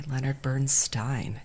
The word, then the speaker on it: Leonard Bernstein: My mentor, one of my other mentors, says it rhymes with Steinway, Leonard Bernstein. Did he get mad if we said Steen instead of Stein? – Who cares?